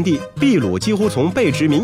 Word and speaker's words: 秘鲁: 因此，一直以来，秘鲁的国名都是秘鲁。据说啊，是当地一条河流的称呼。而在中文里，秘鲁这个发音的缘由呢，则是因为地名的音译，西班牙语中的 p 发的是 b 的音，所以秘鲁才叫秘鲁，而不是秘鲁。